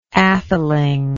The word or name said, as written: aetheling